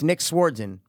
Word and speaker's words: Nick Swardson: Yeah, sword, but with an A. So it's Nick Swardson. – Swardson? – Like a sword. Swardson. And then son.